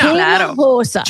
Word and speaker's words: Hinojosa: The H is silent. – Hinojosa. Hinojosa. Gina. – Hinojosa.